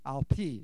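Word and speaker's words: al pi: Al-pi is how you say that in Hebrew.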